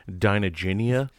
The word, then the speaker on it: Dinoginia: Dino-gina. – Dino-gina. – Yeah. – Wow. – You're welcome. You're welcome. – Sorry, I think you mispronounced that. It's Dinoginia. – Dinoginia. – Gina. – It's like Virginia.